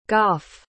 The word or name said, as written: goff